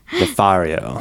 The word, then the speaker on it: Nefario: Nefario.